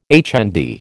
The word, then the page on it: HND: American English